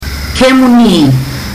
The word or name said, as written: kemuni